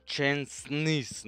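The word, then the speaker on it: Szczęsny: Kāpēc tu čeburti izrunā? – Kur? Čensnīs. Nu, man, nu, es nu, negribu, es visu dzīvi, vēl komentējot, es to nekad neatcerēšos. Es tā visu dzīvi saucu, šesnīju, tā arī saukšu tālāk.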